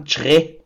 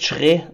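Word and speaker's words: Kyereh: und ich ärgere mich, weil ich es vorhin selber falsch ausgesprochen habe, eigentlich sagt man Chre. Also oh. sein Name wird Schre ausgesprochen. So hat er es uns auf jeden Fall erklärt.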